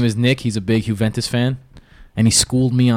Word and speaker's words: Juventus: Juventus. How do you say it? – Juventus.